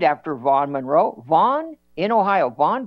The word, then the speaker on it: Vaughn: Vaughn, V-A-U-G-H-N, Vaughn. And sometimes Vaughn is spelled V-A-U-G-H-A-N.